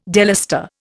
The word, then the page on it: delustre: Indian